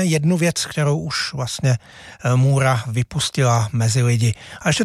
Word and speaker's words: Moorach: Zahrajeme si pražskou kapelu Můra, psáno Moorach. Vyslovuje se to prostě klasicky Můra.